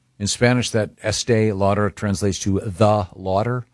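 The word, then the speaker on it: Estée Lauder: Is it pronounced Estee Lauder, Christy? – Estee Lauder, yes.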